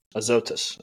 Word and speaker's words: Azotus: But Philip was found at," I'm going to butcher this, "Azotus, and passing through, he preached in all the city." Azotus? – Azotus. – Azotus. All right.